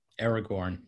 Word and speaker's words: Aragorn: Aragorn? How do you say it? Aragorn? – Aragorn, yes.